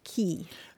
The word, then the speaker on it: quay: Yeah, it's an odd one, but Q U A Y is usually pronounced key.